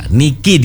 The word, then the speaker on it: Niki: Niki. – Ya, Niki dibacanya ya, tapi kalau orang enggak tahu apa ini Nick gitu. Oke, kenapa pakai nama Niki, Bu? – Ni itu saya ambilkan.